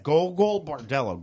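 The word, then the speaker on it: Gogol Bordello: Golgo Bordello. – It's read as Golgo Bordello.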